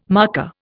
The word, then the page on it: marker: Indian